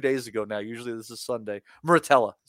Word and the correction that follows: Murataya: Murataya. Excuse me. It's not even pronounced Muratella.